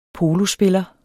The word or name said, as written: polospiller